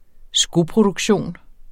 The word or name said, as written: skoproduktion